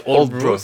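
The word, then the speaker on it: Aldeburgh: Oui, ça s'appelle Oldbro, ça s'écrit Aldeburg, mais ça se prononce Oldbro,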